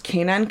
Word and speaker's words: Canaan: Anyway, after her graduation from high school, her mother witnessed a horrific murder and they decided to move to New Canaan, I think is how it's pronounced, Canaan, Connecticut.